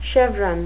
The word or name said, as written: chevron